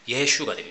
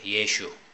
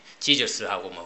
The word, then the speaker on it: 예슈: 유대인들은 그 히브리어에 근거해서 이렇게 발음을 하는데 그 히브리어식으로 예수님 이름을 발음하면은 예슈가 됩니다. 예슈. 예. 지저스하고 뭐 완전 다른 발음이죠.